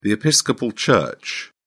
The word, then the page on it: episcopal church: British English